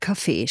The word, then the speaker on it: Kaffee: Das Wort Kaffee spricht er selbstverständlich mit Betonung auf dem zweiten Vokal aus.